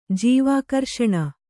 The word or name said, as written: ಜೀವಾಕರ್ಷಣ